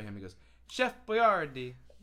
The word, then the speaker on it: Boyardi: Probably the way you're pronouncing Boyardi. It's Boyardi.